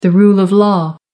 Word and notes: rule of law: American English

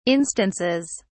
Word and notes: instances: British English

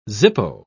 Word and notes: zippo: British English

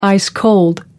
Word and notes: ice-cold: American English

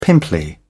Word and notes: pimply: British English

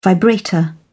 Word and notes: vibrator: British English

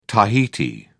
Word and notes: tahiti: British English

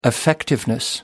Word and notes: effectiveness: British English

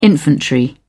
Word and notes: infantry: British English

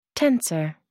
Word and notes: tensor: American English

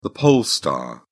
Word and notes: pole star: British English

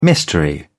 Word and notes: mystery: British English

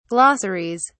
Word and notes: glossaries: British English

